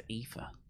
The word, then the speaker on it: Aoife: I think it's Aoife. – Aoife. A-O-I-F-E.